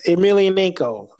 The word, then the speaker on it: Emelianenko: I don't know how to pronounce his last name, but yeah, Emelianenko.